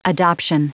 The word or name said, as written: adoption